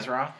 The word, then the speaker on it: Azeroth: Azeroth? Yeah. Well, I don't even think that's how you say it.